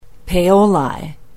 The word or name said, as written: paoli